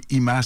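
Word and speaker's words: Yma: Ima, ça commence avec un Y, mais ça s'écrit comme ça se prononce.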